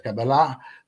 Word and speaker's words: Kabbalah: Quindi questi interessi per la Cabala, chi affetta di conoscere l'ebraico, non io ovviamente pronuncia Kabbalah, ma il termine è una spia della formazione ricchissima che agisce e della varietà di fonti che agiscono in Bruno.